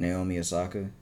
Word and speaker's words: Naomi Osaka: Oshikaka. Oh, I just pronounced it wrong. What is it? – Naomi Osaka. Yeah, then I think she lit the torch.